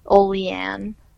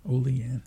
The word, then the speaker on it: Olean: We overpronounce our A's, so it's Olean. – Olean.